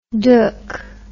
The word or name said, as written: doek